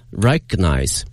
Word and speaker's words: recognize: 那么这个单词的拼写呢，我们看一下，是 R-E-C-O-G-N-I-Z-E，它的发音是 recognize。 Recognize，注意一下那个 g 这个音呢，不要念得太清晰了， recognize，这就听起来有点别扭哈。